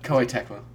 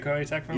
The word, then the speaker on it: Koei Tecmo: Koei Tecmo. – Is it Koei Tecmo? – Yeah.